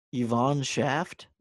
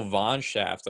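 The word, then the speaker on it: von Shaft: Yvonne Schaft? – No, Von Schaft. Like, she's German.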